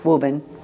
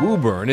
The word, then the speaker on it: Woburn: Woben. I don't say my R's, but it's Woburn. – The weird woo in Woburn is a point of pride around here.